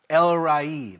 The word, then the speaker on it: El Roi: El Rai, that's how it's pronounced.